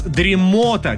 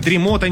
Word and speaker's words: дремота: Ну и, наконец, дремота. Конечно же, дремота никакая не дремота, и это тоже приносит вам 1000 рублей.